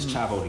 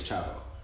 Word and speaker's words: Chavo: It's Chavo the Kid. So my artist name is Chavo.